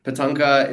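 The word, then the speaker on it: Patankar: Patanka is how I pronounce it, but I don't even think that's like the actual traditional way of pronouncing it.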